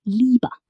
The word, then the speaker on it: Liebe: Answer: a long E sound, like in Liebe, meaning love.